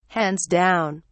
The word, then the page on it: hands-down: British English